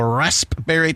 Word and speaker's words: raspberry: National Raspberry Tart Day. No one pronounces the P but me.